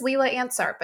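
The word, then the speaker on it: Leila Ansart: And my name is spelled, I'm laughing because I was going to say just Lila Ensart, but no, that'll be too hard. So it's L-E-I-L-A. Last name is A-N-S, as in Sam, A-R-T.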